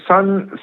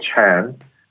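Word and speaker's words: Seon: Okay, so Sun Sun is uh, uh, in in Japanese it's called Zen. So Sun is really the, the Korean version of Zen Buddhism, which I think is more familiar to, to people out in the United States.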